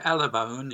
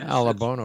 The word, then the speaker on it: Alabone: And Richard, am I pronouncing your family name correctly? – Yeah, Alabone, yes. – Alabone, okay.